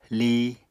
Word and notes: li: Apatani

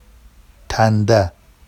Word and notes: tanda: Vafsi

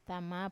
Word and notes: tama: Wamesa

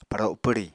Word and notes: paro pwri: Apatani

